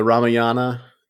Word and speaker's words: Ramayana: Yeah, and it could, that garb could be inspired by Lord Rama from the Ramayana, the Ramayana, if you want to pronounce it correctly.